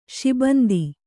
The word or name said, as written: ಶಿಬಂದಿ